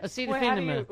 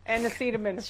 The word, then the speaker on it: acetaminophen: Acetophen. – What is that word? – Acetaminophen. – Ooh, that's a hard one. How do you say it? Acetaminophen, sir. Acetaminophen.